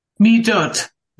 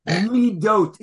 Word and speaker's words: Midot: Midot. – Midot is correct.